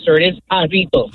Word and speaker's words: Jarritos: Pronounced Jartos. – What happened? – Pronounced Jartos. – Well, I guess if you're a dumbass, it is. But no, it's Hispanic, so it's pronounced Jarritos.